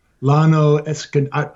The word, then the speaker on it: Llano Estacado: Llano Estacado. Estacado? – Llano Estacado.